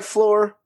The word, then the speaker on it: Flore: that's Lore with an F at the beginning. So it sounds like Floor, but it's not the floor you're thinking, because it's our floor.